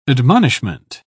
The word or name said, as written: admonishment